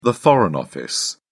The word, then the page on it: foreign office: British English